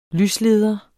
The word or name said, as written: lysleder